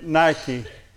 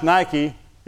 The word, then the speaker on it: nike: N-I-K-E. Like the tennis shoe. It's not pronounced Nike. It's pronounced Nike. You want to make a friend of the word by saying it? Nike. It means victory, success, dominance, absolute victory over one's enemies.